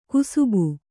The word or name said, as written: ಕುಸುಬು